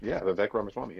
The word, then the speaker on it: Vivek Ramaswamy: Vivek Ramaswamy, am I saying it right? – Oh, you have been, Vivek Ramaswamy, yeah.